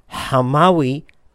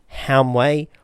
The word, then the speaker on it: Hamawi: And one of the Hamawi brothers in the Shuk mentioned this famous geographer and biographer as proof that their name should be pronounced Hamawi and not Hamway or Hamway or anything like that.